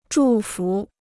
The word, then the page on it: 祝福: zhù fú